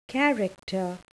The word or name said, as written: character